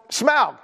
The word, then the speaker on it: Smaug: And it's Smaug.